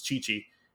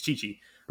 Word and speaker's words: Tite: I think it's Chichi. It's not it's spelled tight or tite or I think it's Chichi. – But it does make a ch sound, huh? – Yeah, and okay. With Brazilian Portuguese.